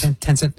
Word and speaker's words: Tencent: they've been supplying, they've been supplying AI services to Alibaba, Baidu, Tencent. Am I pronouncing that right? – Yes. – Tencent.